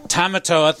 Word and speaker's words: Tamatoa: I have who plays Tamatoa.